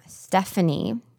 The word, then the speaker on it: Stefanie: For instance, my name, Stephanie, is spelled with an F instead of a PH. It is spelled S-T-E-F-A-N-I-E.